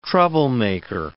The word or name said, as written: troublemaker